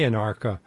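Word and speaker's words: Anarcha: The title of your book is Say Anarka.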